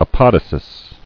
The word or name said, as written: Apodosis